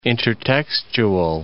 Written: intertextual

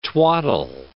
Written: twaddle

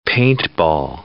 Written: paintball

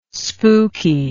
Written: spuky

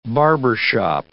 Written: barbershop

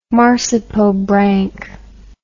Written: marsipobranch

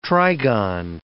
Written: trigon

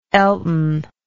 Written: elton

